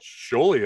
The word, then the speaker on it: Sjolie: Sjolie, yeah, that is correct. It's a unique, Sjolie, different last name.